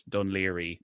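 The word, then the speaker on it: Dún Laoghaire: I'd always tell people it's pronounced Dunleary, you know, or like even like they'd see Port Leash or things like that, and they wouldn't know it.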